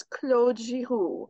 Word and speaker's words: Claude Giroux: For all the Flyers fans listening who don't know the proper pronunciation, his name is Claude Giroux.